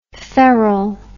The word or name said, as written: Ferrel